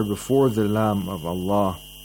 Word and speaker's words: Allah: Qulillah, the word Allah is pronounced with tarqiq because there is a letter with a kasra before the word Allah.